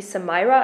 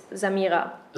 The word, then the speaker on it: Samira: No, you know, when you're pronouncing it English, it would be Samira, I guess. – Samira. – But that wouldn't work for me. You know, in German, it's Samira.